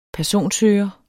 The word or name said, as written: personsøger